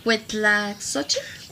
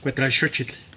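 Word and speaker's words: Cuetlaxóchitl: recuerda, en náhuatl la X se pronuncia como SH. – Sí, pero me cuesta trabajo. Cuetlaxochitl. – Cuetlaxochitl. Como si le estuvieras diciendo a alguien. – Cuetlaxochitl.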